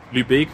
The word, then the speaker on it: Lübeck: Lübeck wird eigentlich lang ausgesprochen, aber auch Mecklenburg-Vorpommern. – Mecklenburg-Vorpommern. – Das ist das sogenannte Dehnungs-C.